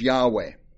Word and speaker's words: Yahweh: One of the names is the YHWH, which we pronounce as Yahweh.